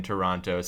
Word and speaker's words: Toronto: Toronto, signaling that he's an outsider and not to be trusted.